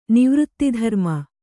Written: ನಿವೃತ್ತಿಧರ್ಮ

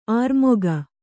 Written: ಅಱಮಗ

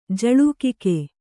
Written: ಜಲಕಾಕ

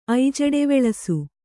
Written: ಐಜಡೆವೆಳಸು

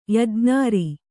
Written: ಯಜ್ಞಾರಿ

